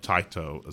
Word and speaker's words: Taito: Or Taito is how it's pronounced, actually.